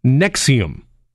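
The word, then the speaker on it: NXIVM: For two decades, he was referred to as quote-unquote Vanguard by his followers in a group pronounced Nexium.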